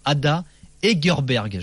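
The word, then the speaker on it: Ada Hegerberg: Je rappelle le nom du premier ballon d'or féminin, Ada Egerberg. Je l'ai mal prononcé tout à l'heure.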